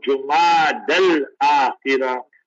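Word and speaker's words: Jumada al-Akhirah: So the way it looks like, most probably we will say Friday will be the first of Jumad al-Akhirah. That's the most authentic way of pronouncing this month.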